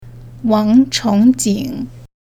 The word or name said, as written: wang chong jing